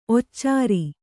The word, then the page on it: ಒಚ್ಚರ: occara